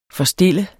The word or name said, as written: forstille